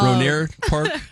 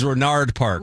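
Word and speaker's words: Rohnert Park: Ronert Park? Ronard. It was Ronard Park.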